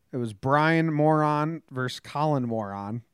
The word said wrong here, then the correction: Moran: it's Moran. Okay. okay. Okay. The real name, the real pronunciation is Moran.